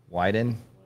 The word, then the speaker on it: Wyden: We met Ron Wyden.